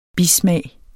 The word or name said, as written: bismag